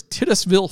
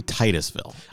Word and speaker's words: Titusville: Then, in late February, he was ready to try again, and this time made it as far as Titusville, Florida, setting a new record. – It's probably Titusville. – I like to pronounce it Titusville. – Okay. Well, you were wrong, but that's fine.